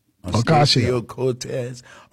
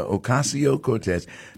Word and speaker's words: Ocasio-Cortez: Representative Alexandria Ocasio-Cortez. – Well, pardon my mispronunciation there, Ocasio-Cortez, and leaves the door open for a 2028 presidential bid or primary challenge to Schumer, to Chuck Schumer.